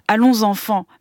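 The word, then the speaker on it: Allonsanfàn: D'abord, je vais le prononcer à la française parce que ça vient de la Marseillaise quand même. Allons enfants, mais qui s'écrit Allons Afan, des frères Taviani, dont on vient d'entendre un extrait.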